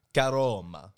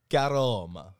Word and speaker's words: Caroma: Caroma. – What? – Caroma. – Spell that, please. – Caroma. C-A-R-O-M-A.